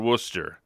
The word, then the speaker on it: Worcester: it would be called Worcester. Now, I'm not sure how it's pronounced in Ohio. Karen, help me out. – It's pronounced the very same way, and it's W-O-O-S-T-E-R.